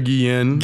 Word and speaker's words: Guillen: Jose Antonio Guillen. – Guillen. It's just the two L's. I want to pronounce everything. – Guillen. Yeah. – Guillen. Yeah. It's Guillen.